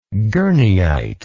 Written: gurneyite

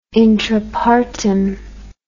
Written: intrapartum

